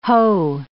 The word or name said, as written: hoe